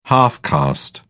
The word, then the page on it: half caste: British English